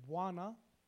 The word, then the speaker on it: bwana: The first word is bwana. It's like bwana.